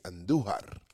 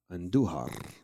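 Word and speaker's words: Andújar: Roll those R's. – Roll your R's when you say Andujar. – Andujar.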